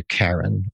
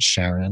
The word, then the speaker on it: Charon: And then you said you also serve as a Karen or a Charon, however you want to pronounce it, Sharon.